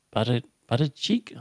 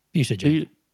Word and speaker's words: Buttigieg: What's his – Buttigieg. – Buddha. What is it? – Buttigieg. – Beijing. No, it's pronounced different to that.